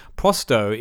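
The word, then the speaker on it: Pro100: Prosto is how, apparently, you pronounce Pro 100, so I've been saying it wrong all this time.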